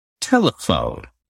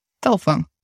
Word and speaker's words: telephone: And also notice that in English, it's not telefon, but telephone. The second E is reduced, almost you do not pronounce it. – Right, telephone.